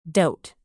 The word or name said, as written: dote